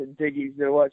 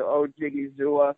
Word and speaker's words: Odighizuwa: So, and then it's just really, I just broke it down to Odighizuwa. So, Odighizuwa.